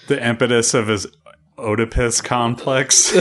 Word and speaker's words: Oedipus: I keep wanting to pronounce the O, but it's Oedipus.